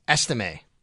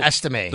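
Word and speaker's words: Estimé: We have the correct pronunciation. It's Estime. Estime.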